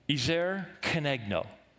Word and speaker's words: Ezer kenegdo: Ezer kenegdo. That's the Hebrew pronunciation of, of, of the words fit or suitable helper.